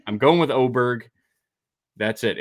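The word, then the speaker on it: Åberg: It was Aberg, then it was Aberg, then it was Ober. Now it's back to Oberg. Uh, so I, I I can't. I'm done. There's been four pronunciations in four months. I'm sticking with the last one I heard that PJ Radio was just uh, saying all week long.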